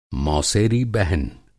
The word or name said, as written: मौसेरी बहन